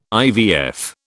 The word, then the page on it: IVF: British English